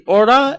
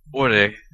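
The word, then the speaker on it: Ore (俺): In the standard pronunciation, based on the Tokyo dialect, it would be Ore and Janai. – Right. – Which become, in Goku's dialect, Ora and Jane.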